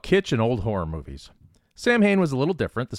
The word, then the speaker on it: Samhain: Don't know how many of you are fans of Glenn Danzig, but he famously led three different bands, The Misfits, Samhain, and Danzig. And yes, I know the correct pronunciation is Samhain, but that's how he pronounced it.